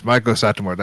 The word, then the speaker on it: Maiko Satomura: Yes, Maiko Satomura. That's how I pronounce it.